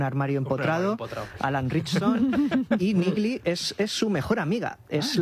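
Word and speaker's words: Neagley: Bueno, pues si os parece, vamos con la siguiente, que es Niggly. ¿Lo he dicho bien? – Niggly, sí.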